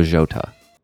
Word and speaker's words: Jota: How to say Diogo Jota. It's Diogo. Jota. – Jota. – Yes.